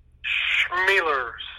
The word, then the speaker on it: Schmeler: Schmeler. C-H like Chicago, long E.